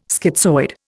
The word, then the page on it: schizoid: Indian